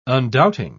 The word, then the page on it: undoubting: British English